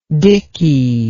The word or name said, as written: dickey